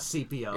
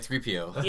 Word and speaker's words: C-3PO: Not C-3PO. – It's 3PO. Oh, if you're going to say it right, 3PO. – Yeah, there you go.